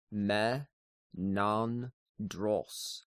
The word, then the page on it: Menandros: slower